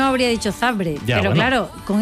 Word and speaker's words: Zabrze: ¿Por qué sabes que es Zabrze? ¿Por qué pronuncias Zabrze? – Porque es así.